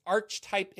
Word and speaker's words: Archetype: I pronounce it Archetype. – Archetype. Okay. Yeah. Again, it's like Covenant of the Ark. Ark of the Covenant.